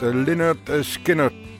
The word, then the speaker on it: Lynyrd Skynyrd: Ça s'est pronounced Lynyrd Skynyrd.